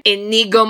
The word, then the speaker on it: Inigo: Ingo, how do I say his name? – Inigo Montoya. – Inigo. Okay, so Inigo is somewhere.